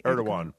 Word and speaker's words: Erdogan: Erdogan.